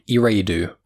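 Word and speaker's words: Eriadu: So issue two starts with Valance and his droid on a planet called Eridu. I think I always pronounce it wrong. It's E-R-I-A-D-U.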